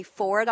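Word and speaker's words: Foard: First up on the panel is Jay Ford. I'll spell that in case you're wondering. It's F-O-A-R-D.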